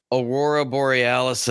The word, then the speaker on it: aurora borealis: But she goes to this like riverfront pier and it's like there's a aurora borealis in the sky. Aurora or whatever. And yep, you said it right.